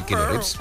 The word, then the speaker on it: Keanu Reeves: Eso es eso que se pierde, es el actor Keanu Reeves, o como se pronuncia. – Sí, Keanu Reeves. – Ah, oh, lo he dicho bien.